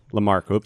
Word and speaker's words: Lamarck: But the workout that I'm looking at right now is called Lamarck. That's how you say it. Lamarck.